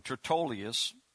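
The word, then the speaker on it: Tertullus: That's how you pronounce his name, Tertullius.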